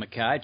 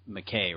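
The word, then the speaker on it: MacKaye: So, guys, let's shift to the BMT, the Benton McKay Trail. Sometimes improperly stated as McKay, right? – Yes. – Yes. – But it's actually McKay, if I have my pronunciation correct. – Yeah, that is correct.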